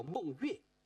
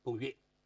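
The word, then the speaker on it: 孟说: 这个大力士叫孟月，说话的说读作月，孟月。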